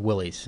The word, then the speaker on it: Willys: There was one from Wiley um, am I saying that right? I think it's Willys.